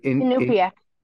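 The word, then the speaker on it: Iñupiaq: how do you say the name of the is it Inupiaq. – in Inupiaq? Thank you.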